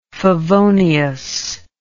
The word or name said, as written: favonius